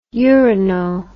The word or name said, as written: urino